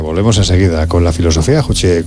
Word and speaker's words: Juche: Que lo ha pronunciado mal. Juche.